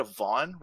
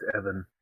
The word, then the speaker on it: Evan: The female lead in Westworld, is it a Vaughn, Rachel Woods, or is it Evan? – I think it's Evan.